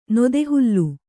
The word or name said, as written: ನೊದೆಹುಲ್ಲು